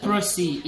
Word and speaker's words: Prossie: Prossie is my name.